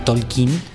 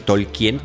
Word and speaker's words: Tolkien: Y que así se pronuncia, gracias a la película, nosotros siempre hemos dicho Tolkien, digamos que fonéticamente como se escribe, pero en la película él es muy enfático, el personaje que interpreta, eh, el personaje de la película que es Tolkien, eh, cómo se debe de pronunciar